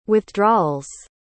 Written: withdrawals

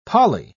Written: poly

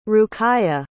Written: ruqayyah

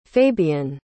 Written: fabien

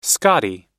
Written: scottie

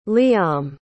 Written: lyam

